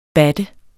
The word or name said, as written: BATTET